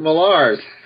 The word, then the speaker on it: Millar: Clint Magazine is now going to be labeled, at least on the magazine, Mark Millar's Clint Magazine. I guess Mark. – His name is pronounced Mark Millar.